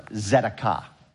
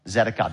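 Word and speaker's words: tzedakah: All right, it's a word, zedekah. Zedekah, the T is silent. Zedekah.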